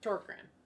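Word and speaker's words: Tåkern: But it has a little English. O, or it has a little, like, dot or period over the A, which causes it to be Torkrin.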